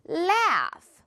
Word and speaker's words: laugh: 자, 두 번째 단어는 이것도 스펠링은 GH 들어가 있지만 발음은 어떻게 된다? 그냥 스펠링상 F가 있다 라고 생각하셔도 돼요. L, A, U 그리고 F다 라고 생각하세요. Laugh, 웃다 라는 뜻이죠.